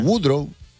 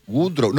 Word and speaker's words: Woodrow: Woodrow. Woodrow. No. Woodrow es un nombre... No sé si sabes, debe ser anglosajón. Que es como si era wood de madera. Siempre él decía, yo me llamo wood, madera, row, remo. Wood, row, W-O-D-R-O-W.